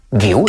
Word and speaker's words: Guiu: G más U más I, gui. Si le añades una U, guiu, ¿de acuerdo? Si la joven promesa del Barça se llama Marc Guiu, es exactamente igual de fácil de pronunciar que en castellano.